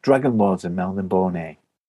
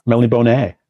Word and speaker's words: Melniboné: The Dragon Lords, and Melniboné. – Oh, right. Melniboné. Is that how you say it? – That's how you say it now, yeah, apparently.